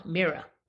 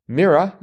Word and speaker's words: Mira: Another place where I was natict, 39 down, actress Sorvino was Myra. – Mira. – Mira. Mm-hmm. And again, just the fact that you corrected my pronunciation means you knew that.